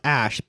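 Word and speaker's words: X Æ A-12: Well, so I think Elon was on like Joe Rogan Experience and was like, he was just like, yeah, it's pronounced Ash, but the numbers are for like a rocket and a plane that he really likes.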